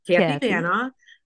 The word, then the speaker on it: Kathy Vana: I said it wrong. Yeah, I'm so sorry. Okay. Dude, we just talked about this. Vana. – No worries. – Kathy Vana. Okay, you're 62. – Like Vana White.